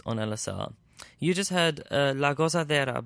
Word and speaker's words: La Gozadera: Could you help me with that, the pronunciation? – Yeah, it's called the... Gente de la Zona featuring Mark Anthony, and it's La Gozadera.